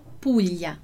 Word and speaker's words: Puglia: E a nossa pronúncia aqui do Puglia vai ser qual pronúncia? Vai ser do LH. Então, eu vou falar, né? Eu vou dizer esse som dessa forma, como se fosse, se tivesse escrito assim, Puglia. Mas aquele detalhezinho que a gente falou antes, a gente vai escrever no italiano Puglia, sempre com o GLI.